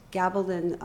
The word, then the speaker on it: Gabaldon: Gabaldon.